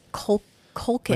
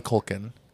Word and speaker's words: Culkin: It's just Culkin. – Macaulay Culkin. – Culkin. – Yeah. – What's his brother's name? It starts with like a K or something, like Kieran or something. – Kieran Culkin.